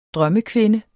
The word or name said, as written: drømmekvinde